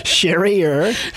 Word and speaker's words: Charrier: But Charrier is the butchered French that my people have taken on. – Je suis la Charrier. Okay, so Charrier.